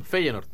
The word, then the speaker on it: Feyenoord: Feyenoord. – Del Feyenoord, sí, Feyenoord es la pronunciación.